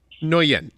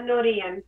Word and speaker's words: Nguyen: Nguyen. It's harder to pronounce it if all your tongue is told R. A lot of people say Nguyen in English, but my full name is Sara Nguyen.